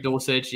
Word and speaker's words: Dulcich: Is it Dulcich? Is that how you say it? – Yeah. Yep. – Greg Dulcich.